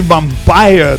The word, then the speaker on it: Vampyr: Y de 1985, el disco Cry for Metal de la banda de Alemania Vampyr, que se puede decir Vampire también. Por eso se escribe Vampyr.